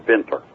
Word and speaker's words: Winter: Belonged to a German guy named Winter, mm-hmm. – properly pronounced Winter.